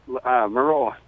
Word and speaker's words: Maroa: I'm mispronouncing it, aren't I? – Maroa. – Maroa.